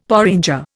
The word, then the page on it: porringer: Indian